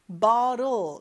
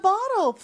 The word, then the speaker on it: bottle: 발음이 bottle도 되지만 주로 북미권에서는 bottle 이렇게 T를 좀 부드럽게 발음한다고 했어요. Move the bottle, please.